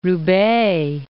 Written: Roubaix

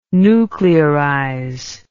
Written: nuclearize

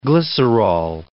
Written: glycerol

